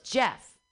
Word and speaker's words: Geoff: Oh, G-E-O-F-F? – Yeah. – Or that's pronounced Jeff. – Right. But it wasn't his name. – No, he wrote J-E-F-F.